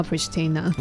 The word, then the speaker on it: Pristina: 呃，科索沃首都叫 Pristina。 – 那 Pristina 就是它，因为它中文写普里森，对，普里斯提纳之类。